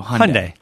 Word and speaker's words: Hyundai: I heard it rhymes with Sunday, so Hyundai. – Hyundai, yeah.